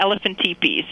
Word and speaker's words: elephantipes: Yeah, elephantipes.